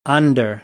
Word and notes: under: American English

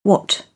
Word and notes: what: British English; ɒ

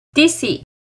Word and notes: tici: Náhuatl

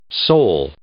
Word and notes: sole: adjective

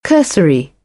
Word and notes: cursory: British English; ɜː

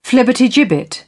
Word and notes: flibbertigibbet: British English; ə

